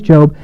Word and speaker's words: Job: And then I realized, long O. Okay, there was a man in the land of Uz whose name was Job, and that man was blameless and upright, one who feared God and turned away from evil.